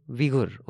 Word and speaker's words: Uyghur: How do you pronounce this, by the way? – Uyghur, okay. Oh, so the U is pronounced as we. I've been pronouncing it wrong all this time.